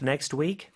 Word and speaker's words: next: Next week인데 next의 요 T 발음은요. 사실 발음이 안 되는 경우가 많아요. 모음 이 아니라 자음 소리 사이에 낀 T나 D는 생략이 되는 경우가 있거든요. 그래서 여기서도 next week, next week 이렇게 발음할 수 있습니다.